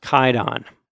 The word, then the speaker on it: Kwaidan: I'd like to point out that spelling, Kwaidan, Kwaidan, that's like a weird historical thing, but it's actually pronounced Kaidan.